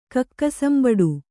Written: ಕಕ್ಕಸಂಬಡು

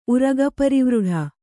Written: ಉರಗಪರಿವೃಢ

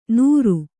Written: ನೂರು

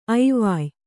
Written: ಐವಾಯ್

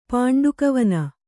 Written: ಪಾಂಡುಕವನ

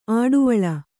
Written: ಅಡುವಳ್ಳ

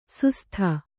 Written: ಸುಸ್ಥ

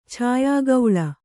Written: ಛಾಯಾಗೌಳ

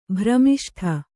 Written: ಭ್ರಮಿಷ್ಠೆ

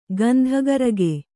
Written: ಗಂಧಕಾರಿಕೆ

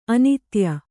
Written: ಅನಾಧೇಯ

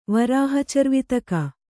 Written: ವರಾಹಚರ್ವಿತಕ

